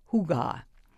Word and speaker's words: hygge: Indeed, the most fashionable Scandinavian import this season is the intangible, the Danish concept of hygge, sometimes pronounced hygge.